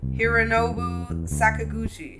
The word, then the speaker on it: Hironobu Sakaguchi: Hironobu Sakaguchi. Did I say that right? – Yes, you did.